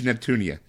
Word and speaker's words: Neptunia: Neptuna. Rebirth 1 by Idea Factory for the PS Vita. I think I said that right. – Yeah, Hyperdimension Neptunia.